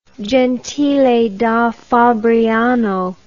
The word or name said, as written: Gentile da Fabriano